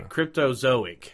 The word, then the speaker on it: Cryptozoic: Well, I know Cryptologic. – Right. – Yeah, I think that I'm pronouncing it. I thought it was Cryptozoic. – Yeah, Cryptozoic.